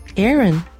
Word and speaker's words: Aaron: air と run を続けて Aaron と発音するといいですよ。